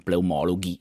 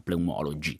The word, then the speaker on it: pneumologi: perché è un accento sdrucciolo, non pneumologhi ma pneumologi.